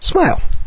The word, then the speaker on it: SMIL: In fact, the top hit is Synchronized Multimedia Integration Language, spelled SMIL, pronounced SMILE.